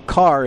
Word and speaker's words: Kahr: For those of you who are relatively new, Carr is spelled K-A-H-R.